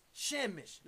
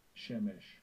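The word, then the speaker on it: Shemesh (שֶׁמֶשׁ): Now, the word sun in the African Hebrew is Shemesh. Let's hear the pronunciation. – Strong's H8121. Shemesh.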